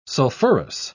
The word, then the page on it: sulfurous: British English